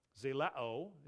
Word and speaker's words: zelao: Zelao is the Greek pronunciation.